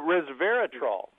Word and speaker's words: resveratrol: And finally, Restorol. Was it? I mispronounced that probably. Restorol, or the grape, or whatever. – Oh, resveratrol.